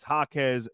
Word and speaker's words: Jaquez: For your chance to win, text the word Jaquez, J-A-Q-E-Z, to 20357. That's Jaquez, J-A-Q-E-Z. Did I say that right? J-A-Q-U-E-Z?